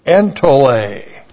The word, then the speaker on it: entole: E-N-T-O-L, long E, entole.